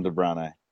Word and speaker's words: De Bruyne: I've always called him De Bruyne. – Okay. – Well, that's how you say his name. – De Bruyne? – De Bruyne. That's how it's spelled.